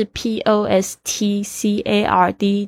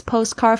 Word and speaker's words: postcard: this postcard 就是这张明信片，大家特别注意一下这个 postcard，虽然是 p o s t c a r d，但是请不要念成 postcard，postcard，postcard 就可以了。 Can you mail this postcard for me？